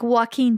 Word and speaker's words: Joaquin: Like Joaquin Phoenix. – What's that? – The actor. – Oh, shoot. I think that's how you pronounce it.